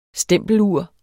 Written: stempelur

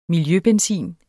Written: miljøbenzin

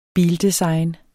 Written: bildesign

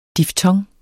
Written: diftong

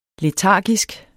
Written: letargisk

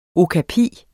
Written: OKAPIEN

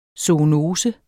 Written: zoonose

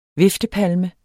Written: viftepalme

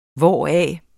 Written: hvoraf

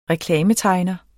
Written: reklametegner

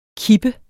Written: kip

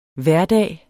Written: hverdag

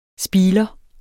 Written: spiler

